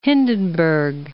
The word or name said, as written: Hindenburg